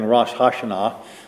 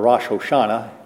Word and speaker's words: Rosh Hashanah: Some calendars, even today, if you look at it, besides having Rosh Hashanah, or Rosh Hoshanah, whichever way you wish to pronounce it, on the calendar, it will also say Jewish New Year.